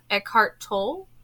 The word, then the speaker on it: Eckhart Tolle: And that is from Eckhart Tolle.